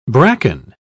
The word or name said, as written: bracken